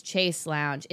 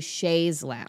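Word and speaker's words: chaise longue: I corrected Millie's pronunciation of Chasse Lounge, and I said it was Chase Lounge. It's actually Chaise Lounge.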